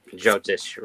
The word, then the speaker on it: Jyotish: But tell us about the, and I am learning how to say it, Jyotish. – Jyotish, right.